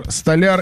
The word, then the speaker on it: столяр: Как правильно говорить? Столяр, столяр или допустимо оба варианта? – Оба. – Столяр, правильно говорить.